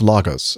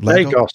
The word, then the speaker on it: Lagos: My question is for Captain Nick, because he flies into and out of Lagos. Did I say that right? – Lagos. – Lagos. Darn it.